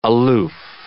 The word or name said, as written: aloof